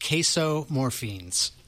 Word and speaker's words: casomorphins: It's pronounced casomorphines.